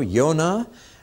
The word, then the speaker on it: Jonah: We're going to look at Jonah and Nahum, or as they should be pronounced in Hebrew, Yonah and Nahum.